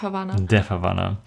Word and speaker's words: Death Havana: Genau, und zwar, zwar ging es um, wie spricht man sie richtig aus? Ich habe es immer falsch gemacht. Um Death Havana. Death Havana.